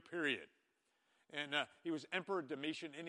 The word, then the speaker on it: Domitian: Domitian is how it's pronounced.